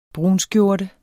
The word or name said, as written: brunskjorte